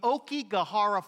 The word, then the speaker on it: Aokigahara: How many of you have ever heard of the Okigahara Forest? Anybody ever heard of the Okigahara Forest? Well, it's spelled A-O-K-I-G-A-H-A-R-A.